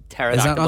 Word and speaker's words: pterodactyl: Pterodactyl begins with a P. – Really? – Mm-hmm. It's a silent P at the start of it.